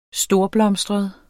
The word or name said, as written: storblomstret